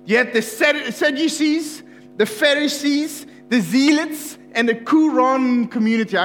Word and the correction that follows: Qumran: I know I'm not saying that correctly, and it's funny. Qumran, there, Qumran community.